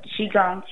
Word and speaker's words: qigong: Just type in qigong, and it's spelled Q-I. Okay, it's pronounced qi, like the qi in your body. It's Q-I, and G is in good, O-N-G. So qigong for beginners is my favorite.